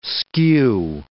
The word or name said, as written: skew